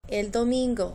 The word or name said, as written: el domingo